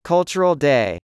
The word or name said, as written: Cultural Day